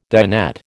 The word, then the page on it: dinette: American English